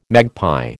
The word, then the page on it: magpie: American English